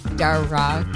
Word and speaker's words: Darragh: Darragh. – Darragh? – That was beautiful. Darragh? I wish it was that Darragh. Exotic. It's Darragh, yeah. – Darragh. – Like Sarah. Darragh.